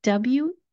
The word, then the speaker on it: Wednesday: Try not to focus on it and think of the spelling as being Wenzday.